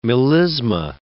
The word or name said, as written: melisma